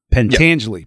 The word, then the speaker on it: Pentangeli: And then, like you said, like, I'm going to mispronounce it, but Pentangeli? – Pentangeli. Yep. Perfect.